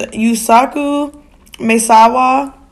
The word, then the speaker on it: Yusaku Maezawa: So a Japanese billionaire named Yusaku Maezawa.